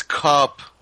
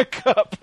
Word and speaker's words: Kup: Yeah, I think that's what's going on with Coop here, whose name is spelled K-U-P. Cup. – It's Cup. – Yeah, Cup. – It's like the Transformer.